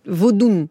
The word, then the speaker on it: Vaudoun: Et d'ailleurs, vous faites tous les deux partie de comités scientifiques, que ce soit du projet de musée international du Vaudin. – Vaudou. – Alors, pourquoi on met un N au Bénin ? – Vaudoun, pardon, il faut le prononcer comme ça.